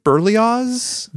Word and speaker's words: Berlioz: It's spelled B-E-R-L-I-O-Z, Berlioz? – Berlioz.